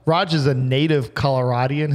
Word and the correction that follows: Coloradan: Is that how you say it? – Coloradan. – Coloradan.